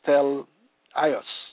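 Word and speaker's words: tel-ios (τέλειος): The Greek word for perfect in this text of Scripture is teleios, pronounced tel-ios, meaning complete of labor, growth, moral character, of full age, men perfect.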